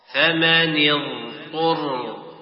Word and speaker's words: اضطر: يطوف، إذ تبرأ الذين اتبعوا من الذين اتبعوا ينعق، فمن اضطر، نعم، أولا تنطق ب الضاء، بالضاد المستطيلة، فمن، ثم تنطق بعد ذلك الطاء، فمن اضطر.